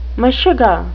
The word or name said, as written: meshuga